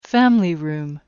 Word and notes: family room: American English

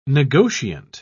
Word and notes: negotiant: British English

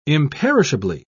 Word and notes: imperishably: British English

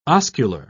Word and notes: oscular: British English

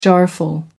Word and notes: jarful: American English

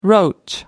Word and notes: roach: American English

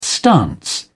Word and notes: stance: British English

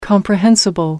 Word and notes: comprehensible: American English

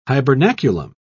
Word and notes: hibernaculum: British English

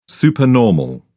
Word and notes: supernormal: British English

